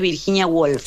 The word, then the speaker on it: Virginia Woolf: No sé si lo estoy pronunciando bien, pero es Virginia Woolf. – Sí, está perfecto. Sí, un cuarto propio. – Está bien pronunciada.